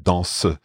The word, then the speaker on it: danse: And I think it was one of the first times I'd heard this idea of on y danse, because I'd always thought of danse as one syllable, but danse, it's kind of reinforcing this fact with song that you can actually stress that, well, not stress the second syllable, but recognize the second syllable.